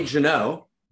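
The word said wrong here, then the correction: Guénon: right? Guy, I never pronounce his name right. I'm Genon, Rene Genon, yeah, his the traditionalist.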